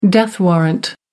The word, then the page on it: death warrant: American English